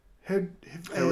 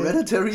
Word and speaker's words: Hereditary: Der hatte ziemlich viele ähm, Parallelen sogar zu äh, Her Her Hereditary.